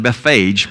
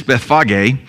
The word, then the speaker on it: Bethphage: So these two villages, the Bethphage, I know you'd want to pronounce that Bethphage, but that's English. It's Bethphage, which means house of figs, and Bethany, which means house of affliction.